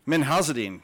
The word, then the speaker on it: Minhazuddin: Minhazuddin.